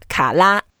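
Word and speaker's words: ka la (卡拉): And the way to pronounce it, it's just like La La Land. La. – La. – Yeah, ka la.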